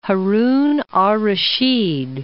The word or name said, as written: Haran ar-Rashad